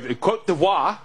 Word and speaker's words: Côte d'Ivoire: I mean, look, Côte d'Ivoire is how you put it, I guess, is the proper way to pronounce it.